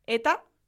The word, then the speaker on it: eta: Y es que aunque se escriba eta, eun eta bat, eun eta bi, se pronuncia como si fuese da. – Ah. – ¿Por qué? Porque cuando eta va después de una palabra que termina en L, el sonido cambia y pasa de ser eta...